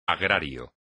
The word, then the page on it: agrario: espagnol